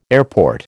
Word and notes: airport: American English

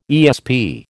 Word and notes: ESP: American English

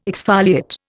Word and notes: exfoliate: Indian

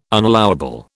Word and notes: unallowable: British English

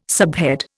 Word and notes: subhead: Indian

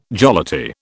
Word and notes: jollity: British English

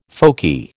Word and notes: folky: American English

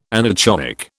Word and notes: anechoic: British English

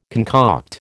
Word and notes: concoct: American English

